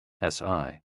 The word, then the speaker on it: Si: SI, pronounced like sir, with a slow exhale, which is associated with the lungs.